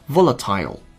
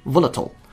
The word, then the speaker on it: volatile: 没错，它的形容词叫做 volatile，或者是 volatile。那 volatile 这个发音呢是英音，volatile 这个发音呢是美音。